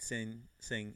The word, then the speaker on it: Singh: You don't pronounce. I'm trying to pronounce the H and I shouldn't. Jagmeet Singh,